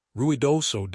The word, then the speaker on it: Ruidoso: Ruidoso Downs, pronounced R-W-I-D-O-H-S-O, was recently forced to close due to a damaged culvert, which effectively paralyzed the entire facility.